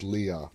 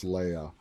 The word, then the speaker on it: Leah: I think the right way to pronounce it is Leah. I keep going to my Star Wars, and it's like Princess Leia. But it can be either Leah or Leia.